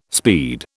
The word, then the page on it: speed: British English